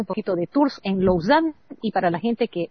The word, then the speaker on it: Lausanne: Se escribe Lausanne. Se pronuncia Lausanne.